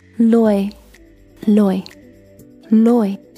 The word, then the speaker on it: loyal: So L-O-L是 loyal loyal，通常我会听到身边的朋友们说 loyal，有有这个声音很容易说出来，因为在中文里就有这个音，比如说左右的右，可是在这个单词里面没有右这个声音。 loyal 有，如果仔细听的话你会发现它的元音是不一样的。 loyal loyal 在 loyal 这个单词里面发音是没有右这个声音的。 loyalWe can practice it slowly. 我们可以慢慢的练，而且把音节分开来练。Loyal 这个单词有两个音节，loyal，第一个 loy loy loy，第二个 yo yo yo loyal loyal loyal loyal loyalty。